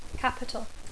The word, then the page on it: capital: English